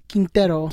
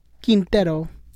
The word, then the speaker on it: Quintero: Darwin Quintero. – Quintero? Just because of Darwin... – Quintero.